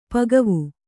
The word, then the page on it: ಪಕ್ವ: pakva